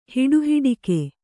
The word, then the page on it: ಹಿಡಿಹಿಡಿಕೆ: hiḍi hiḍike